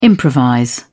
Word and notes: improvise: British English

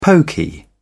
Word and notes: poky: British English

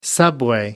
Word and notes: subway: American English

